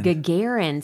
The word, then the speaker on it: Gagarin: I remember hearing somewhere that the cosmonaut Yuri Gagarin? – Gagarin.